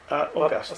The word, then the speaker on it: Augustine: Augustine asked, or Augustine, how do you prefer it pronounced? Augustine? – Augustine. – Let's go with Augustine.